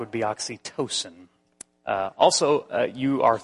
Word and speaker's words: oxytocin: Uh, the correct pronunciation of it is oxytocin and that is also called the cuddling hormone.